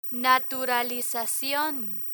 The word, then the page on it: naturalizacion: Spanish